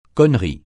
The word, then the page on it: connerie: francais